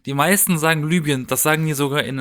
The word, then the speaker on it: Libyen: Aber das ist ja wie wenn man sagt, Also korrekt ausgesprochen würde es Libyen heißen.